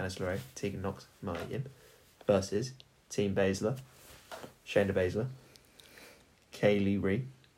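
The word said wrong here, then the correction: Kay Lee Ray: Said that wrong. – Kaylee Ray.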